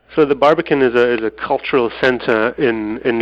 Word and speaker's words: Barbican: When I was in London earlier this month, you told me about the Barbican. I suppose I'm saying that right, where you live.